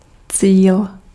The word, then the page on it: cíl: [tsiːl]